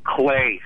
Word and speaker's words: Clay: Clay, C-L-A-Y. You have it correct.